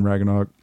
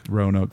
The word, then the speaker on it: Roanoke: Like it's a new street pretty much over there in Ragnoke. I'm sorry if I'm saying it incorrectly. – Roanoke? – Roanoke.